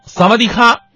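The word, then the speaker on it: 萨瓦 迪 卡 (สวัสดีค่ะ): 你好，萨瓦迪卡，就是萨瓦迪是你好的意思，这个卡是不一样的，卡是女人独有的发音，它是一个词缀，女人发音是萨瓦迪卡，男人的词缀是 club，最后一音是要闭合的，所以应该是萨瓦迪 club。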